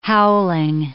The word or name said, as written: howling